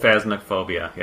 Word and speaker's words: Phasmophobia: Are you talking about phasmophobia. – Phasmophobia.